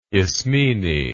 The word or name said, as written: ismene